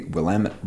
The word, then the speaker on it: Willamette: It is the 29th edition of the annual festival that takes place on the Waterfront Park and the banks of the lovely Willamette River in downtown Portland, Oregon. – Rhymes with damn it.